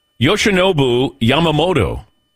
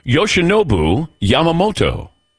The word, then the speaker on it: Yoshinobu Yamamoto: By the way, I've been practicing a pronunciation here. And everybody's going to have to do this. All right. Yoshinobu Yamamoto. Once again, Yoshinobu Yamamoto. – Yoshinobu Yamamoto? – Yes.